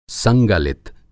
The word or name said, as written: संगलित